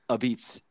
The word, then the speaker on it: apizza: I'm sure many will because they're foodies, but some might know what a beats is. Can you tell us for what that is for a second? – Yeah, so New Haven style pizza is referred to locally as a beats, and it's spelled a pizza, which is the way I have to describe it to people.